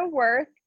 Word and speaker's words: Werth: So my name is Victoria Werth. Werth is spelled with an E-W-E-R-T-H.